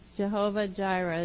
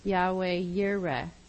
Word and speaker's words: Yahweh Yireh: And He says that He reveals His name there as Jehovah Jireh, is how we pronounce it in English, but it would be Yahweh Yireh, and that means the Lord who sees.